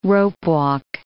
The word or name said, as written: ropewalk